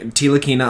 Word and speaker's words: Ntilikina: A straight up trade for Frank Ntilikina.